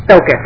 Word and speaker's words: Focus: Focus. It rhymes with focus.